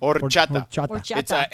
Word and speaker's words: horchata: Horchata. It's silent is H is silent.